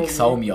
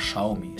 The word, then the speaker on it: Xiaomi: Ich habe das immer Xiaomi ausgesprochen, das heißt einfach Xiaomi.